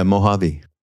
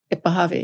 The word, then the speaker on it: Mojave: Yeah, Mojave. – Mojave, yes.